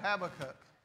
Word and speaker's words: Habakkuk: I cannot discuss this if I don't know how to say it. Y'all don't understand. Hold on. – Habakkuk. – Okay, there we go. Habakkuk.